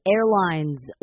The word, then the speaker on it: airlines: 首先，第一个字 airlines，我们最后一个s 要把它念成z的音 airlines。好，再来看下面这个字 merged，我们把它念快一点的话是 merged，但是你会发现这个后面的那个d 是有出来的哈，我们会说 merged，它是有点像t 的感觉，merged。